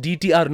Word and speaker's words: DTR: So it's pronounced DTR, not dutter, dutter.